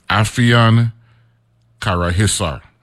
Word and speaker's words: Afyonkarahisar: I'm going to spell it for you. AFYONKARAHISAR, right? And it's in Turkey, Turkey, right? Right? And pronounce it as Afyon Karahisar.